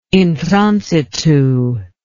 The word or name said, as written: in transitu